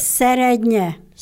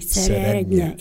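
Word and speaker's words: Serednye: How do you say the name of that? – Serednya. – Serednya. I'm not going to try that one again. – Well, it's S-E-R-D-N-E. Serednya.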